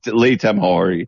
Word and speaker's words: Lee Tamahori: Lee Tamahori.